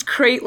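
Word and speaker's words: krayt: I thought it was crate like a wooden box. – That's how it's pronounced. It's, yeah, oh, okay. – It should be said. It's K-R-A-Y-T.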